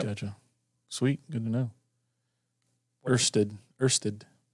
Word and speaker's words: Ørsted: So, from my Google box I have over here, so I wanted to know, how do you pronounce that O with the line? – Okay. – That is like in bird. – So it's like Ørsted?